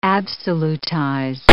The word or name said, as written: absolutize